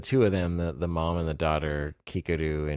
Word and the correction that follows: Kikuko: Am I saying it wrong now? – You're saying it wrong. – I knew this was going to happen. – Nikuko. – Oh, Nikuko and Kikuko. – Kikuko. Yeah.